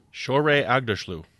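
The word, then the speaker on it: Shohreh Aghdashloo: Shaurei Agdashloo. – Shaurei Agdashloo.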